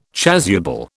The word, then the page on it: chasuble: British English